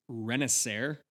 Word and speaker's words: Renacer: It's Renacer, R-E-N-A-C-E-R.